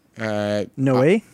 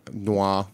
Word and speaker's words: Noé: How do you pronounce his last name? – Noé. – Yeah, people say Noé, Noir. Noir. It depends because he's in France. They're going to say Noir.